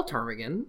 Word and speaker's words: ptarmigan: And being illiterate miners from all over North America, they could not spell ptarmigan, which if you're familiar with the word, has a silent P at the beginning.